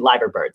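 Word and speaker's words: Liver Bird: They have a just simplified Liverbird now on their uniforms and everything, and I don't really It's Liverbird. We had a very nice YouTube commenter on our Liverpool video. He wasn't too nice, but he pointed out that we were saying it wrong. So it's actually Liverbird. So there you go. It's Liverpool, but it's Liverbird. Because it has to do with the name of the building or whatnot.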